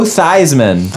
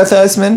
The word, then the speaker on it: Theismann: Oh, did we already talk about how I met Joe Theismann? – Well, we talked about the Theismann and the Theismann and the changing it to rhyme with Heisman and the whole nine.